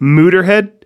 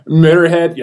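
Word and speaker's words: Motörhead: So are you telling me we're supposed to be pronouncing it Muterhead? – Yeah. Muterhead. Yeah. Yeah.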